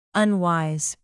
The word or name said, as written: unwise